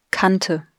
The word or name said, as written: Kante